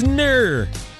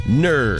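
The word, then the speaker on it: gnurr: That term is NUR. NUR with a silent G. G-N-U-R-R. NUR.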